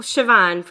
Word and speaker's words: Siobhan: Well, Siobhan, first of all, is how you pronounce that.